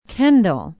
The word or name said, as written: kendall